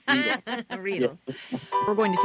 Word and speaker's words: Riedel: Because I said Riedel like an American. How is the proper pronunciation? – Well, it rhymes with needle, so Riedel.